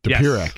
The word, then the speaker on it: Dopirak: Dopirak.